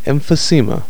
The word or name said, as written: emphysema